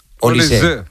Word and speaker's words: Olise: Es no lleva acento, pero se pronuncia Olise. – Olise. O sea, es apellido francés. – Sí. – Vale, es que no sabíamos si lo de francés era por el padre o la madre. O sea, que es pero es raro que si no lleva acento que se pronuncie Olise. – Claro, ¿no? Si no lleva acento sería Olis. – Es Olis.